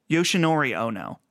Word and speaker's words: Yoshinori Ono: Yoshinori Ono.